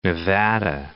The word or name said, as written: Nevada